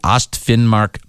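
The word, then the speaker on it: Østfinnmark: The Svein is the operation leader for the Ostfinnmark Police District. – I love it when he says foreign words. – I do too. – How would you pronounce it? F-I-N-N-M-A-R-K? – Ostfinnmark.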